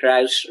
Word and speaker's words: Ruysch: Frederick Ruysch. – Is that? I think that's fairly okay. I think, yeah, Frederick Ruysch, Ruysch.